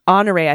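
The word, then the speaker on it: Honoré: I think he pronounces his last name like Honore, silent H, French accent on the E. He identifies as Creole. Oh, was I saying it wrong? Honore.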